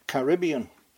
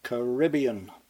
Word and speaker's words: Caribbean: First, let me just pat myself on the back for having finally schooled myself to give this word the American pronunciation, Caribbean. Back in Airstrip 1, we used to say Caribbean.